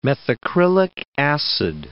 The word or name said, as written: methacrylic acid